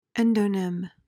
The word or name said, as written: endonym